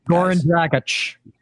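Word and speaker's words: Goran Dragić: Goran Dragic.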